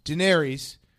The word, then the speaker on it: Daenerys: Daenerys is on one.